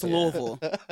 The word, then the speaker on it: Louisville: But, so pronounce it right. It's Louisville.